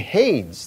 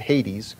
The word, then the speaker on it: Hades: And so I looked, and behold, a pale horse, and the name of him who sat on it, was Death, and Hades, that is the grave, followed, or some pronounce it Hades, followed with him.